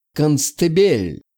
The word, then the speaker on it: Констебель: С легкой руки Киото и остальные командиры стали называть меня на английский манер «Констебель». С ударением на второе «Е».